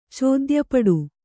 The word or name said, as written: ಚೋದ್ಯಪಡು